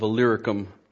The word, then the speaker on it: Illyricum: And I just wanted to say you nailed the pronunciation of Illyricum.